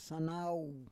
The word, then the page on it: sanau: Roon